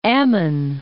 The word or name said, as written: Ammon